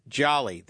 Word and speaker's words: Jouley: They nicknamed it Jolly. That's spelled J-O-U-L-E-Y. Named after the Joule unit of energy.